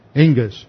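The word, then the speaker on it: engus (ἐγγύς): That's the Greek word engus, E-G-G-U-S, and the G double gamma is pronounced like an N-G, engus.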